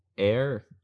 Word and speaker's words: err: It's not error. It's err. Err on the side of caution. – Air. That doesn't make any sense. – That's the saying. It's not spelled A-I-R.